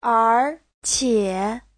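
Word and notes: erqie: er2qie3

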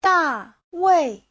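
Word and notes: Dawei: da4wei4

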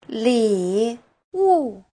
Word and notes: 礼物: li3wu4

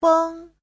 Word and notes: feng: feng1

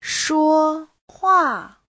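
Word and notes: shuohua: shuo1hua4

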